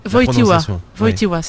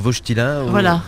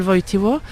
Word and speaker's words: Wojtyła: Wojtyła, c'est la prononciation juste. – Nous, on disait plutôt Wojtyła. – Mais la vraie prononciation, c'est Karol Wojtyła.